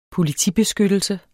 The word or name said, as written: politibeskyttelse